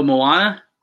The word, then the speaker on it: Moana: Oh, Moana. – Moana, that's how you say it. I knew I wasn't saying it right.